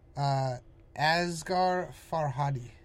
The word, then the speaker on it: Asghar Farhadi: Asghar Farhadi.